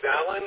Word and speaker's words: Alan: My name's Alan.